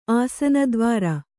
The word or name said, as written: ಆಸನದ್ವಾರ